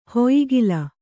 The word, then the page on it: ಹೊಯಿಗಲ: hoyigala